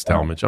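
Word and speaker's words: Tallmadge: It's actually Tal. – Talmadge, sorry. I said Talmadge, and it's Talmadge. I'm sorry. – Although the word is spelled tall, like T-A-L.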